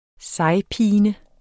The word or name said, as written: sejpine